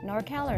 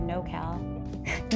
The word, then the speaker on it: NorCal: Which do you think people prefer more to say? NorCal or NoCal? Nor. I know, right? Because it's North. Have you ever heard NoCal? Because, you know, it's supposed to rhyme with SoCal.